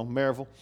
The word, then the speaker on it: Maryville: That's how you say it when you're from there. V-U-L, you know, Maryville. And so, not Maryville. So she grew up around there.